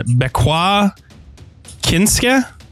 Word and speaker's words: Bequa Kinske: Bequa Kinske? – Sounds good to me.